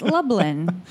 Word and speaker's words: Lublin: Well, we first said Lubin, and then we thought we corrected ourselves to Lubin. – But we don't know how to read. It's Lublin.